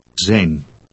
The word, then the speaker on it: zijn: How do you say his? – Zijn. Zijn.